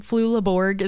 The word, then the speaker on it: Flula Borg: Flula Borg.